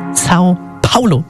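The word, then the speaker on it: São Paulo: Sao Paulo, so spricht man es aus.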